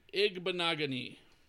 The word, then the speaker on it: Igbinoghene: Noah Igbinoghene.